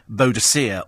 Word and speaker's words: Boadicea: Boadicea or Boudica, depending on which pronunciation you like.